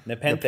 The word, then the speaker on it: Nepenthe: So then they discover that the malfunctioning droids were all served the same batch of Nepenthe. Nepenthe? Am I saying that right? – Nepenthe. Yeah. Nepenthe. Nepenthe. Yes, you are.